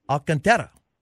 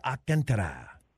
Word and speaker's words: Alcantara: I'm your host, and joining us this morning is Alcantara. Did I say that properly? – That's very close. It's actually a city in Spain pronounced Alcantara. – Ah Alcantara.